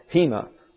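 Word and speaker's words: hina (ἵνα): Now, this is your rough breathing, and it comes down as an H. That's simply the accent on the first syllable. This is an iota, and that's an I. This is a nu, and that's an N, and that's an alpha, and that's an A. So it's pronounced hina.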